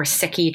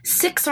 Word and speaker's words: Sikh: So a really good example here is Sikh or Sikhi traditions. That's S-I-K-H. So some folks in America might pronounce that Sikh, but the correct pronunciation is Sikh.